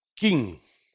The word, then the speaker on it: Qin: Se escribe Qin, pero se pronuncia en mandarín Qin.